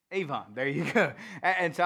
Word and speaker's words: avon: But it's the Hebrew word, it's pronounced avon. Avon. There's my southern accent ruining me from speaking good Hebrew. But it's spelled A-V-O-N.